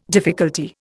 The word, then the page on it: difficulty: Indian